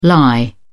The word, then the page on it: lye: British English